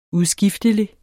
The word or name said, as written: udskiftelig